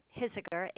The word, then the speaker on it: Hisiger: Hisiger, H-I-S as in Sam, I-G-E-R, 18902.